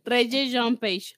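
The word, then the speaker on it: Regé-Jean Page: es, que no hemos, es, que no hemos, hablado de que sale Regan Page, casi se pronuncia, amiga. Reggie John Page, ajá.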